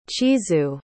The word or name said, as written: chizue